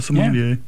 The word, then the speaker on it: sommelier: Yeah, a sommelier.